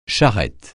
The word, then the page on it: charrette: français